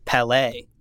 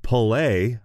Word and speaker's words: Palais: Is it Pelé? – Why did I throw myself into this sentence? – Wait, Pelé pronunciation. You know, oh, we can look this up. We have the internet. – Is it a word? – Pelé. – Pelé. Okay. The French.